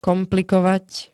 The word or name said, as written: komplikovať